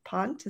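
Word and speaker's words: Ponte: It's Pizza Pont. Pont? Is that how you pronounce it? – Yes.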